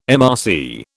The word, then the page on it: MRC: British English